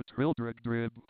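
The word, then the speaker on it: Trildrogdrib: It contained a warrant for conducting me and my retinue to Trildragdub, or Trildragdrib, for it is pronounced both ways, as near as I can remember, by a party of 10 horse.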